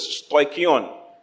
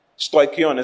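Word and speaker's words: stoicheion: The word principles is stoikion. Stoikion is spelled S-T-O-I-C-H-E-I-O-N. S-T-O-I-C-H-E-I-O-N. S-T-O-I-C-H-E-I-O-N.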